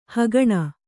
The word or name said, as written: ಹಗಣ